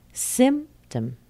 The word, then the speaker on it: symptom: As in the word symptom. Symptom. Spelled with a T-O-M, but the sound is tum. Symptom. Or system. System. Spelled T-E-M, but the sound is tum.